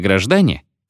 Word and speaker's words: граждане: Обыкновенная совпублика, пестрая многоликая масса, что носит у московских кондукторш название «граждане», ударение на втором слоге, ездит в трамваях.